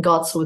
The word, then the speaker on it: Godsil: So my name, it's spelled a bit funnily, Jillian with a J and Godsil with G, G O D S I L.